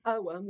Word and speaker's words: Ogham: It's spelled O-G-H-A-M, like Ogham, but you pronounce it Oham, like Oham money.